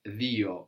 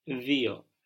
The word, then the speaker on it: δύο: But this is pronounced Dio in Greek. – Δύο.